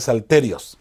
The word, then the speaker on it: salterios: Bueno, no, porque no tiene el acento en la I. Es salterios.